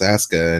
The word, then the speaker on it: Asuka: You'd say that just automatically, and the U is not pronounced, so it's Asuka, and, you know, I mean, in Tagalog and Filipino, you pronounce the U if you want to, you know, I mean.